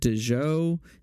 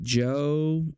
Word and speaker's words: Djo: So my number 9 is the album 2020 by, I'm going to pronounce it Dejoe. It could just be like Joe, and that'll make sense in a second why it might be named that. But it's spelled D-J-O, and it's actually just one guy.